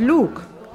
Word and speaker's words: luk: Das Wort Luk bedeutet Bogen, wenn es mit einem langen U gesprochen wird. Mit einem kurzen U ausgesprochen nimmt es die Bedeutung Zwiebel an.